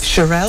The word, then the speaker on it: Shirelles: And then, how would you pronounce that one? The Shirelles. – Shirelles. – The Shirelles. – It is. The Shirelles.